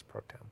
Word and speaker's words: pro tem: I think it's pro tem. – Wow, you don't even say the last syllable. – Speaker pro. – Okay. – I think you shorten it to pro tem.